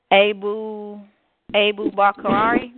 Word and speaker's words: Abu Bakari: Okay, a Mali king named Abu Bakari. That's how you pronounce his name.